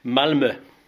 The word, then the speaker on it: Malmö: That's the proper pronunciation, not Malmö. Malmö. We're here Malmö, in the... Yeah. How do you say it? – Malmö. – Malmö. – Correct. – So it's not Malmö, it's Malmö. – Yeah, it's the Swedish letter Ö.